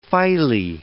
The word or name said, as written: phylae